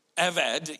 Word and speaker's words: avad: The Hebrew word that is translated work, avad, even though there's a B there, apparently you pronounce it as a V.